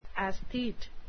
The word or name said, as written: aesthete